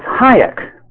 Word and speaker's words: Hayek: Or was it Hayek? And he said, it's Hayek, like high explosives.